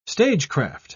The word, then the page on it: stagecraft: British English